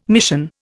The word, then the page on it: mission: Indian